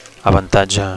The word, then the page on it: avantatge: Catalan